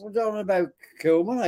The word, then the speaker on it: Kilman: Oh, well, the paper talk or on the back, Kilman. – Kilman. You actually said his name right, not the Klingsman, like you normally say.